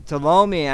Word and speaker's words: Ptolemy: So Ptolemy actually, that's the way, I'm sorry I've mispronounced it so badly before, but Ptolemy gathered an army, Ptolemy the Third, and he marched on Israel and did the Syrians.